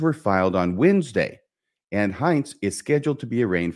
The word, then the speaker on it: Hintze: Pennsylvania State Police have charged Rhett Hintze, that's how I'm going to pronounce it, H-I-N-T-Z-E. The Rhett, I think, is like Rhett Butler, R-H-E-T-T.